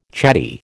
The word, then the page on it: chatty: American English